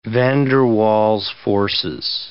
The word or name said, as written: van der Waals forces